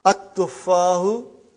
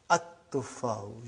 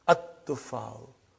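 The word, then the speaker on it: At-tuffau (التُّفَّاحُ): See, there is no shadda. Anta, Dhamma is given. But now your mind knows that that is a sun letter. So you will pronounce as At-tuffau. You will put a shadda Anta. At-tuffau.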